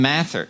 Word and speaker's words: Mather: And we started out, as Reagan said, as a light set on a hill, and he was quoting, I think, Cotton Mather.